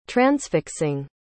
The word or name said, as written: transfixing